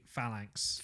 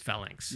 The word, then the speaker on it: phalanx: Is it phalanx? I don't actually know how that word is pronounced, but I'm Australian and my grasp of the English language is somewhat rudimentary. – I- am going to confidently say phalanx. Phalanx. Yeah. Phalanx.